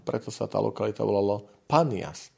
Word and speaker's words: Panias: V Izraeli je lokalita na severe, už takmer teda pri hraniciach e, so Sýriou a to je tzv. panias, alebo banias niekedy vyslovený.